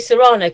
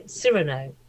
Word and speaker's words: Cyrano: I say Serrano because I've always heard it was Serrano de Bergerac, but the Americans tend to say Cyrano.